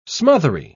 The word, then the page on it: smothery: British English